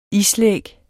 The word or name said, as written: islæg